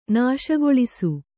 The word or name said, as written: ನಾಶಗೊಳಿಸು